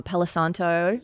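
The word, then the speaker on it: palo santo: Oh, and a good old um palisanto. Yes. – Is that how you say it? – Palisanto, yeah. – Yes, I got it right.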